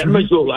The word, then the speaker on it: Mazzulla: Yeah, Mazzulla. I said it right. – You nailed it.